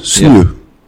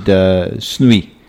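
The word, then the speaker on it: sneeu: In Afrikaans, sneeuw. Yeah. – Huh? – Well, you didn't say it correctly earlier. – No, I didn't. – Sneeuw. – Yeah, I said snoei.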